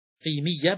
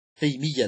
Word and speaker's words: قيمية: قيمية بكسر القاف هكذا تلفظها، قيمية، لما تقول قيمية هكذا تقول قيمية، قيمية،